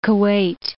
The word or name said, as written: Kuwait